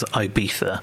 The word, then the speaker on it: Ibiza: She's had, either three, might have even been 4 days over in Ibiza, which I'm going to pronounce it properly as Ibiza, unlike the Wenger boys coming up.